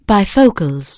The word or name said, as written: bifocals